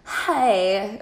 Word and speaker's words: Haigh: Hi. – Hi. – That's how I'm going to say it because it's spelled H-A-I-G-H. Hi.